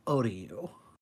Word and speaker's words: Otyugh: And this creature is called an Odiu.